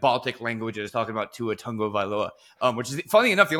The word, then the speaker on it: Tagovailoa: I'm like, you're adding an extra syllable in there. Tua Tagovailoa. That's it.